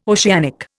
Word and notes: oceanic: Indian

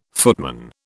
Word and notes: footman: British English

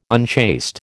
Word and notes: unchaste: American English

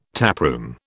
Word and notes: taproom: British English

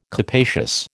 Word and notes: capacious: American English

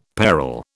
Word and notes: peril: British English

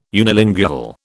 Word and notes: unilingual: British English

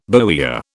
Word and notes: bowyer: British English

